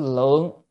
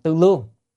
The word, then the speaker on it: tư lương: Phi tư tư lượng, ở đây phải đọc là tư lương, tư lương phân biệt chi sở năng cập, chẳng phải chữ phi là chẳng phải, tư lương à, chữ tư á, có nghĩa là suy nghĩ, chữ lương á, là thẩm đo thẩm định vân vân, cân nhắc và vân vân, tức là như người suy nghĩ tính toán thẩm đo, phải đọc là lương, còn đọc lượng á, là số lượng rồi, cho nên phải đọc đúng âm.